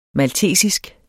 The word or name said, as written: maltesisk